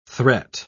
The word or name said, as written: threat